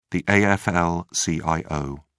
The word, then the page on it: AFL-CIO: British English